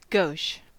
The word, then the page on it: gauche: American English